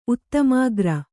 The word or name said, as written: ಉತ್ತಮಾಗ್ರ